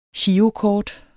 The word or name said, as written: girokort